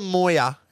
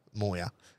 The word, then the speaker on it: Moir: And it's called the Moya, and it's spelt M-O-I-R, Moya.